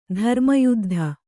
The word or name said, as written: ಧರ್ಮಯುದ್ಧ